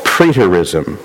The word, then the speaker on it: preterism: And this view is called preterism. Let me spell that for you. P-R-E-T-E-R-I-S-M.